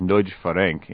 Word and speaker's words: Nagy Ferenc: and he was eventually able to convince Ferenc Nagy, actually that's pronounced Nagy Ferenc in Hungarian, to jump to the U.S., although he later denounced Vajda.